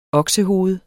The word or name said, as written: oksehoved